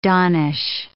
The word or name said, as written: donnish